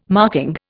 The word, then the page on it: marking: Indian